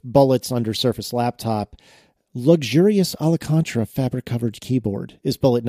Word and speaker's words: Alcantara: You never have to say it out loud when it's in car magazines, and all of a sudden you're faced with this word. You got to do it a syllable at a time. Alcantara.